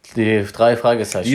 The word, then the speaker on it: Die drei Fragezeichen: Betonung, das muss Betonung was liegt auf drei. Die drei Fragezeichen.